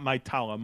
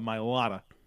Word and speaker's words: Mailata: Bernhard Ryman, Jordan Mailata, not Mailata, Mailata, who's not relevant to this conversation at all, but that's a pronunciation thing that we get.